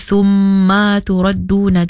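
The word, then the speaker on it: thumma turadduna (ثُمَّ تُرَدُّونَ): Di situ hati-hati ada tempat yang si ada mat ataupun si ada panjang. Jadi kita boleh bacanya jangan dipanjanglah. Oh. Contohnya macam di situ kalimah thumma turadduna. Tadi mungkin si perasan ma-nya ada terpanjang sikit. Jadi thumma turadduna, jadi yasya, hati-hati kena pendekkan kena cukup dulu harkat thumma turadduna.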